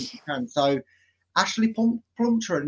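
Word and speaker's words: Plumptre: We are obviously talking about the Nigerian Ashley Plumtree. Now, people might say she's called Plumtree. She's not. It's Plumtree. That's one name I did learn was not pronounced the way I thought it was.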